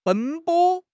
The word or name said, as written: 奔波